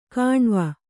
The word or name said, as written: ಕಣುವೆ